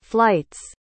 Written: flight's

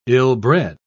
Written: ill-bred

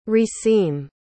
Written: reseam

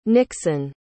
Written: nixon

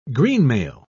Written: greenmail

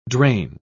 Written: drain

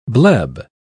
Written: bleb

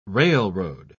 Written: railroad

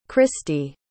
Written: cristy

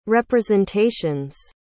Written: representation's

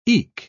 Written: eke

